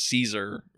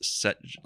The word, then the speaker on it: Cesare: Well, it's written as Caesar, but I'm pretty sure it's supposed to be pronounced Cesare.